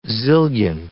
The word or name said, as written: zillion